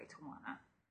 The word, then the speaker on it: Tijuana: Tijuana. – Tijuana. Because remember from our last show when we told you guys how to pronounce it properly, Tijuana.